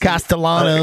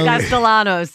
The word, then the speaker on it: Castellanos: Castellanos. – Castellanos.